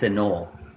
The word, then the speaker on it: Anole: There's an E at the end, is what you're saying. Anole. – Anole. – Ah! – There you go. – There it is. Anole. – Pronunciation from the web.